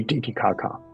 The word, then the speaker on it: Titicaca: And then the sacral chakra is an intersection of the female and the male dragon ley line and Lei Titicaca. Did I say that right? Oh, okay, yes.